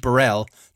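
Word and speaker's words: Borrell: As I finally got to the bottom of how you pronounce that name. And it is indeed Burrell, the more extravagant pronunciation, quite fittingly.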